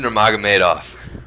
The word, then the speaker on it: Nurmagomedov: Khabib Nurmagomedov.